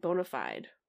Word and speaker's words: bona fide: He pronounced it bona fide. Is that how it's pronounced? – It's bona fide. – It feels like a British thing. – Okay. I always thought it was like bona fide. – I always said bona fide in my head.